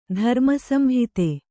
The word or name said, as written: ಧರ್ಮಸಂಹಿತೆ